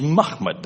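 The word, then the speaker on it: Mahmud (محمود): Mem, mim, ha, mim, dal is Mahmud. That's how you pronounce it.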